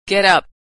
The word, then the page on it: get up: verb